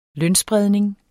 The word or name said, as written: lønspredning